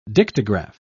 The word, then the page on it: dictagraph: British English